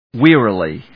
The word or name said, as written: wearily